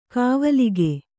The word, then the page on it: ಕಾವಲಿಗೆ: kavalige